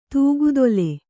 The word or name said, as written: ತೂಗುತೊಲೆ